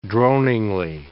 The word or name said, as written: droningly